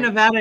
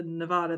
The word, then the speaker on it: Nevada: Which, to be fair, Nevada isn't an English word either. So, but. – Yes. Yes. – Loophole. – Loophole. – And in Spanish, it would be more closely pronounced to Nevada than Nevada.